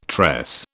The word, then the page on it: tress: British English